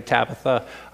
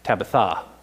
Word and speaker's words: Tabitha: Now, if you named your child Tabitha or you know somebody Tabitha, that's not actually how it's pronounced in the Greek. It's like Tabitha is what it is. Because I have this cool tool in one of my Bible tools that tells me how to pronounce Greek words.